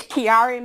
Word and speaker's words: Chiari: I know that you had to live with, and now please excuse me if I pronounce this incorrectly, Chiari malformation. Can you tell us about that? – It's Chiari malformation.